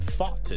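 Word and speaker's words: Pfaadt: It's Brandon Fought, as Scott Lynn pointed out to me. Knows a guy that knows a guy that knows the family and says they've actually, most people have been saying it wrong. So there you go. I always wanted to say Pafad, but that's not... That's not how you say it, right, Scott? – Correct. It's okay, fought, cool.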